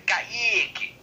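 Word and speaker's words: Kaiky: Kaique que se pronuncia com K. K-A-I-K-Y. Olha o nome do cara, Kaique.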